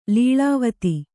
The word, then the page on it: ಲೀಲಾವತಿ: līlāvati; Kannada